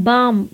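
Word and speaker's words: balm: बाम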